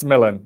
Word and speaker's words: Milan: It's Milan, yeah.